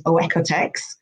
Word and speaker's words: OEKO-TEX: And so I'm a big fan of labels such as Oekotex,